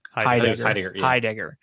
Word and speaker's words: Heidegger: I like it because it established how terrible President Shinra is and how Shinra and Herdiger. Am I saying that right? Herdiger? – Heidegger.